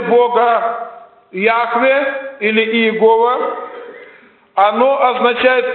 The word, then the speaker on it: Яхве: В русском синодальном переводе оно читается иногда как Иегова. Как Иегова. Но это неправильная система произношения этого имени, это ошибка. В действительности оно должно произноситься как Яхве.